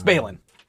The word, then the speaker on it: Balin: It's Balin. It rhymes with the other one.